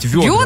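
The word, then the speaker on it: вёдра: Если я не ошибаюсь, это произносится через ё, то есть ведра.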